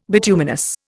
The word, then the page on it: bituminous: Indian